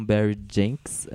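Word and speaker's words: Barry Jenkins: Bom, o filme tem oito indicações ao Oscar, os principais é, melhor filme, direção com Barry Jenks, é isso? Falei certo o nome dele? – Sim.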